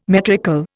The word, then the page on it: metrical: Indian